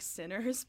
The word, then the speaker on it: CNERS: I always pronounce it Sinners. Is that? – Yeah. – That's okay. No, it does sound like Sinners, but it is C-N-E-R-S.